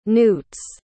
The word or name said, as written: newts